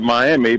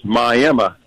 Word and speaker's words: Miami: It's spelled like everybody down in my... in Florida, call it Miami, but here in Oklahoma, we pronounce it as Miami. That's the name of the tribe the city was named for.